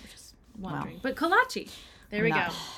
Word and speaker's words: kolache: It's not collage. It's not collage. – It's not collage. Collage. Collage. Okay, guys, whatever you want to pronounce it, they're amazing. K-O-L-A-C-H-E.